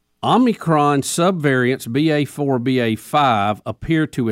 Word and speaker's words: Omicron: How do we say that? Omicron. It's O-M-I-C-R-O-N.